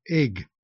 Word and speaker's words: Eigg: So Cotton Eye Joe, and then a group called Horo, the Isle, oh, no, not the, it's just called Isle of Egg, E-I-G-G, pronounced egg.